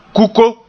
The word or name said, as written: kuko